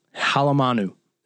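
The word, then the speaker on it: Halemanu: The last one, Vanderbilt, I told you I was really nervous about the first game because I, I figured out how to say his last name too, and Ryan even flexed it as well. Cade Halamanu, Halamanu. It's H A L E M A N U.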